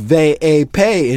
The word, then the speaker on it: VIP: As he approaches the red rope of the VIP, pronounced Ve A Pay in Spanish, he spots his dear friend who is almost certainly going to become his enemy by the end of the film, Smooth Skin.